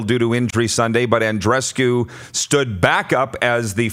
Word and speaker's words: Andreescu: Andreescu.